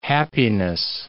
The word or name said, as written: happiness